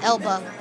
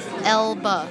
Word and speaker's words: Elba: Is that what I'm saying that right? Idris Elba? – Elba. Elba. – Alba, like... – No. Elba. – Alba like Jessica Alba. – No. Elba like Idris Elba.